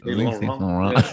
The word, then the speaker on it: Saint Laurent: Yeah, so Yves Saint Laurent. That's how you say it. Saint Laurent. See, this sounds that way you're supposed to say.